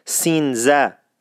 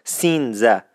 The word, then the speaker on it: سینزه: کلمه بعد عدد 13 هست که 13 در اصفهانی وجود ندارد و به صورتی سینزه تلفظ میشد. تکرار میکنم، سینزه. مثلا 13 به در میشد سینزه به در.